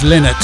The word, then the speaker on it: Lynott: Some quality rock music by Phil Lynott, or is it Phil Lynott? I'm sure in the early 70s he was always pronounced Lynott, so I don't know.